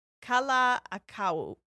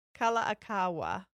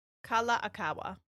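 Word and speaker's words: Kalākaua: His name is King Kalaakau. – Okay. – I've said that wrong. Kalaakaua.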